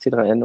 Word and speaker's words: t3n: Das heißt nicht TEN, aber man darf es gerne TEN aussprechen. Es heißt T3N und es kommt von Typo 3 News, weil wir damals ein Nerd-Magazin für Typo 3 Geeks waren.